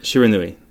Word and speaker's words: Shiranui: Shiranui. – Shiranui. Ranui. Shiranui. – Shiranui.